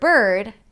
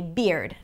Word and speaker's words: bird: So, for example, if there is the word bird, Arabic speakers might say beard. Now, beard is a different word and spelled differently, right? So we want to make sure that we're pronouncing it clearly and not phonetically. So bird, beard, bird, b-er, b-er.